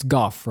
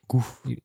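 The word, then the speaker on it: Gough: How would you pronounce that? It's Goff, right? Michael Goff. – Goff.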